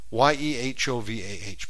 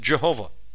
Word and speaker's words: YEHOVAH: YEHOVAH. But here it is. You may have heard it pronounced Jehovah. It's pretty close, but it's Yehovah. And there's a shortened version of his name, Yah, YAH.